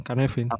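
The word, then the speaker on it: Cynefin: no sé exactamente cómo, se pronuncia porque sé que no es, así, pero bueno, voy a llamarlo Kainefin. – Kenefin. Mm, casi lo has dicho bien, Kanefin.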